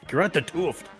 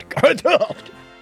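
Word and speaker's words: Gerard 't Hooft: That's how you say his name. – I know. It just I got a T out by itself. – It almost sounds like a coughed. Gerard de Hooft.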